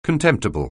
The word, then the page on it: contemptible: anglais